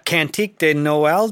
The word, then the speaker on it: Cantique de Noël: O Holy Night, also known as Cantique de Noël, did I say that right? – Yeah, very good.